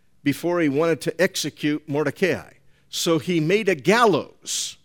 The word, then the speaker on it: Mordecai: Mordecai. I was going to say Mardakeo, but it's Mordecai in English.